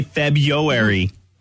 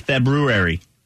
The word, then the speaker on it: February: Don't say February or February. That's wrong. According to this, it's February.